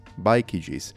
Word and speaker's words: Bikeygees: Bikeygees, that's the right pronunciation.